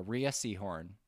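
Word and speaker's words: Rhea Seehorn: I want to get her name right. It is Rhea Seahorn.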